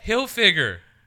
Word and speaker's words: Hilfiger: Hilfiger. – Tommy Hilfiger. – There's no N.